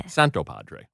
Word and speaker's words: Santopadre: No, I don't say the hard T. – Say it. – Santopadre.